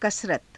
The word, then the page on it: kasarata: Hindi